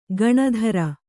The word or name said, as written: ಗೇಣಿದಾರ